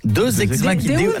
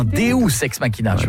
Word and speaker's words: Deus ex machina: Deux ex machina. Non, Deus ex machina. – Ah, parce que deux, j'aurais dit que c'était deux ex que t'appelles machine. Tu vois. – Ça se prononce Deus ex machina.